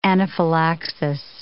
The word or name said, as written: anaphylaxis